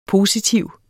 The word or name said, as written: positiv